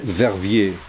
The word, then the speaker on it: Verviers: Verviers.